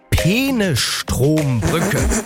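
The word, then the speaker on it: Peenestrombrücke: Es geht um die, und ich versuche das jetzt richtig auszusprechen, es geht um die Penestrombrücke. Ich habe das jetzt bewusst, glaube ich, richtig ausgesprochen. Manuela Schwesig tut sich da ein bisschen schwer.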